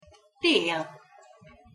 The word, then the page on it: tel: Hungarian